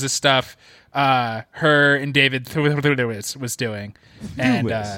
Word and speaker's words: Thewlis: Yeah, it's quite a simple last name. It's two syllables. – Thewlis. I'm so, the Britishness of it all. – It's like the Thames.